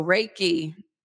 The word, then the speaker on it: Reiki: So Reiki, the last syllable, that ki, that really is what most people are familiar with because we know it as chi, the chi moving through your body.